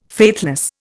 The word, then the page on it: faithless: Indian